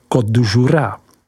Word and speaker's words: Côtes du Jura: Třetí podoblastí je Côtes du Jura, vyslovujeme Côte du Jura.